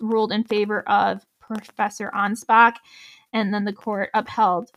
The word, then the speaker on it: Anspach: And that's spelled A-N-S-P-A-C-H, but I looked it up and it says it's Anspach, so.